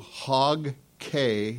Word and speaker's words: Hog Cay: You can go to Hog Cay, Bahamas, and I said it right, not Bay. There is a Hog Bay somewhere, but this is Hog, C-A-Y.